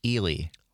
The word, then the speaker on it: Ely: I will say, though, Phil in Minneapolis just called in and says it is pronounced Ely. – Oh, really? Eli. Well, see, immediately, that's another reason the people in Ely don't want me there, is because I can't pronounce their town name.